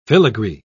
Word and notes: filagree: British English

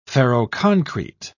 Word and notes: ferroconcrete: British English

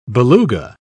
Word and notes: beluga: British English

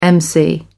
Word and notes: mc: American English

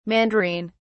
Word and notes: mandarine: British English

